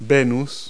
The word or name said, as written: venus